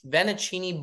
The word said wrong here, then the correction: fettuccine: No, not ven. Not ven. – Fettuccini. – Fettuccini bolognese. – Fettuccini. – Fettuccini bolognese. – No, fet. Fet.